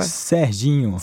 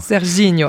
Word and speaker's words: Serginho: Je le dis bien, ou pas? – Serginho. – Serginho, avec le bon accent.